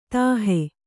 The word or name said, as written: ದೋಹಿ